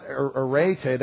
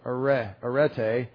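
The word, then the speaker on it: arete (ἀρετή): But pronounce not arete, that's an epsilon, arete.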